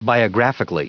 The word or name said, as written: biographically